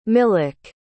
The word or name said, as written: milek